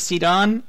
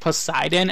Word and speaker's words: Sidon: By the way, I was pronouncing his name as Sidon, and I was kind of like stuck to that. But then I think it was you on Digimoncast who mentioned that, like, Poseidon, and I'm like, that makes so much more sense. I'm gonna start saying Sidon now.